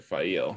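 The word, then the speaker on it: Faile: They pronounce it Fail.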